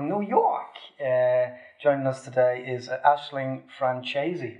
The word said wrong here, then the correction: Franciosi: Am I saying that correctly? – So close. um, Aisling Franciosi.